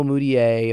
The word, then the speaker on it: Mudiay: I gotta say, like, I think the only Nuggets that I could name right now are like Emmanuel Mudiay, if I'm even pronouncing that right, yes, you are.